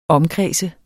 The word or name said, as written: omkreds